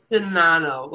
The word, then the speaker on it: Czinano: It is Monica Czinano, like sin, and then just Ano. The Z has no purpose.